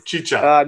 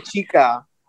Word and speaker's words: Chicha: There's only one way to mispronounce it, right? Is that it? – Yeah, you can just say Chica.